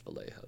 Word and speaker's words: Vallejo: There's two L's. – It's pronounced Vallejo, though.